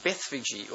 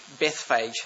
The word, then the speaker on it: Bethphage: in this little village called Bethphage, or Bethphage, however you'd like to pronounce that.